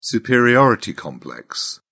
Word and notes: superiority complex: British English

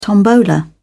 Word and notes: tombola: British English

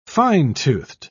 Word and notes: fine-toothed: British English

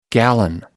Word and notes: gallon: American English

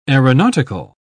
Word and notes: aeronautical: British English

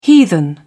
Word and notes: heathen: British English

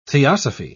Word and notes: theosophy: British English

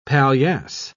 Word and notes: paillasse: British English

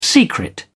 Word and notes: secret: British English

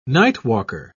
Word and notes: nightwalker: British English

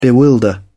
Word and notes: bewilder: British English

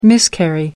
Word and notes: miscarry: British English

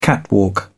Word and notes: catwalk: British English

